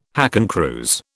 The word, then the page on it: hakenkreuz: British English